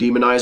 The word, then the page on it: demonize: American English